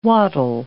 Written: wattle

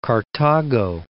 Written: Cartago

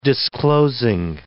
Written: disclosing

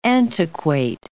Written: antiquate

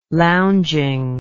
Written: lounging

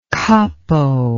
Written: kapo

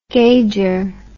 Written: gager

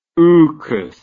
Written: ookus

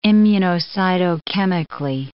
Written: immunocytochemically

